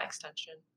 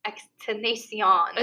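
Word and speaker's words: XXXTentacion: But wait, it's not extension. – No. That hit. Okay, that guy. Rest in peace. He passed away. But his name is pronounced Extenacion. – It's not extension.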